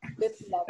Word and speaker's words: Biplab: Because is it actually Biplab, or like, am I even pronouncing it right? – Yeah, it's Biplab. – Biplab.